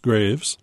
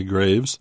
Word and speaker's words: Greaves: it's pronounced Graves, G-R-E-A-V-E-S, Stanley Graves. The painting is titled Morning Mangoes.